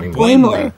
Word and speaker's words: Boimler: Is it Boimler? – No, it's B-O-I-M-L-E-R. Boimler. – Boimler. – Yeah, Boimler.